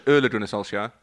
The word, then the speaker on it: Ole Gunnar Solskjaer: Ole Gunnar Solskjaer.